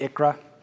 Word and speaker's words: ICHRA: That's a mouthful, so I'm going to phonetically pronounce it ICHRA. It's just a little bit shorter, easier for me to say.